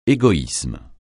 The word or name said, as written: égoïsme